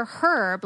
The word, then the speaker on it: herb: And I was like, because he knew I was sick, and I was like, "Did you say herb or herb? Like, how do you say it?" He goes, "No, in Great Britain, you say herb. The H is silent."